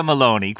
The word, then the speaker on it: Meloni: Giorgia Meloni.